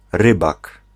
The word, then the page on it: pêcheur: [pɛ.ʃœʁ]; France